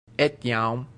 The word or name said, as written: etiam